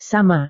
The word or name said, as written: sama